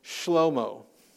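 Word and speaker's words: Shlomo (שלמה): If you want like a, like a direct transliteration of, of Solomon, his name is pronounced Shlomo.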